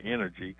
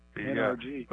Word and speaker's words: NRG: Where people mispronounce it and say energy. – Energy. – NRG. But it was NRG, and they're going when the Houston Stadium signs come down, it's going to go back to Reliant Energy or Reliant Stadium.